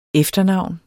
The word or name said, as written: efternavn